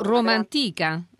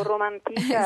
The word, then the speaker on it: Romantica: Cioè, o Roma Antica, secondo dell'accento. – O Romantica, esatto. Sì, è un gioco di parole, sì.